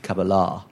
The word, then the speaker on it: Cabella: Just, i just like to point out we did learn from the Sky Sports commentator on Sunday that it's actually pronounced Cabella.